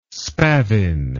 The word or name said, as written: spavin